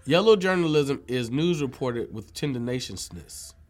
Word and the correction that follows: tendentiousness: Did I say it right that time? – Tendentiousness.